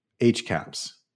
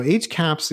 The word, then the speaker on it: H-CAHPS: And CAHPS is spelled C-A-H-P-S, and it's usually termed H-CAHPS, hospital CAHPS. So most people say H-CAHPS. So H-CAHPS is like the very first national standardized publicly reported survey of patient perspectives on hospital care.